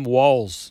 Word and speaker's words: Walz: Tim Walz.